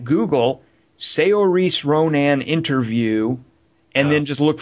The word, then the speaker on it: Saoirse Ronan: Oh, so finally, I'm like, okay, we got to know how to say her name, and we've all practiced, I presume. It's Saoirse Ronan.